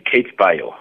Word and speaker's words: CapeBio: So firstly, give us a brief background of, am I saying it properly, CapeBio? – Yes, CapeBio.